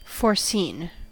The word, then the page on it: foreseen: American English